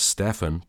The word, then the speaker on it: Stjepan: If you would like to buy Neil's book, this will be in the episode description as well, but you can contact, and I think he pronounced this person's name as Stefan, but it's spelled Stjepan Juras @fanclub.